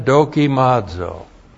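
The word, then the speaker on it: Dokimazo (δοκιμάζω): But, and then dokimazo. Great verb. This is what you ought to be doing. You need to be doing this every single day. D-O-K-I-M-A-Z, long O. Dokimazo.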